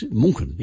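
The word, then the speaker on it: München: It's Munchen. Yes, it's Munchen in Germany.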